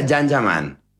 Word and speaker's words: Can Yaman: Se escribe Can Yaman, se pronuncia Yan Yaman.